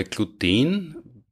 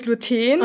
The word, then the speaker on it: Gluten: Das ist, im Duden steht, soweit ich weiß, Gluten, aus dem Grund, dass sehr viele wissenschaftliche Begriffe auf diese betonte letzte Silbe enden. Mhm. Aber wenn man es sprachhistorisch anschaut, mhm. dann wäre es Gluten, weil auf Latein war die Betonung auf der U-Silbe.